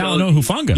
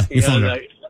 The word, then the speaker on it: Talanoa Hufanga: Talanoa Hufanga. – Yeah, Hufanga.